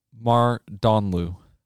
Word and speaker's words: Mardanlou: Mardonlu.